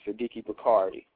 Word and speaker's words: Sadiki Bakari: And they can, uh, my books are available at my website, which is my name, Sadiki Bakari.